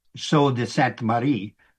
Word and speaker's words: Sault de Sainte Marie: Marie, by the way, try to pronounce it the way the French would have pronounced it, Sault de Sainte Marie, which the French word Sault, at least in Old French, meant falls or rapids.